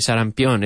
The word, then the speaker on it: sarampión: Y cada kit tiene dos dosis de vacunas contra el sarampión. Sarampión. I hope I'm saying that right. Sarampión. Eso es.